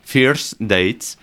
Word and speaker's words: First Dates: A ver que lo pronuncie bien. Fierce Dates. Eso es.